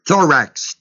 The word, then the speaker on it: thorax: The word breastplate is the word thorax, T-H-O-R-A-X, long O.